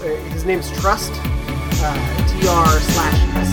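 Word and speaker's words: TR/ST: Are you sure it's pronounced that way? It's not Trust. – It's trust.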